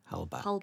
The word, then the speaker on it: Halbach: But in the meantime, he's accused of murdering a local photojournalist, Teresa Halbach, and it is Halbach, isn't it? – Halbach. – I think it's the Welsh in me. Like, I pronounce CH as a...